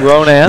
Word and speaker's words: Ronan: Is that Ronan? – That's how they say it. Now, it's Ronan, Montana. I didn't know that till I got corrected the other day.